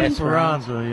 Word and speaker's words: Esperanza: And what about that, I always get it pronounced wrong, Esperanza or something? – Esperanza, yeah.